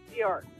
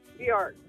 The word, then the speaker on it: Bjork: Bjork. – Can you spell that for us? – Bjork. – B-J-O-R-K? – You got it.